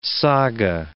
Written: saga

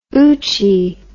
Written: oochie

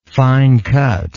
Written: fine cut

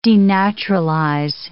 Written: denaturalize

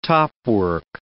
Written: topwork

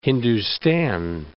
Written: Hindustan

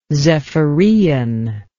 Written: zephyrean

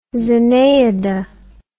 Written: zenaida

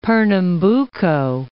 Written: Pernambuco

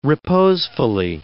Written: reposefully

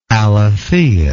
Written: alethia

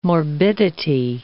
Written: morbidity

